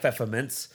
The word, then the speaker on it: Pfefferminz: So it sounds like pfeffermints, but it's spelled with the P F E F F E R M I N Z.